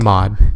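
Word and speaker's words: Maude: Maud.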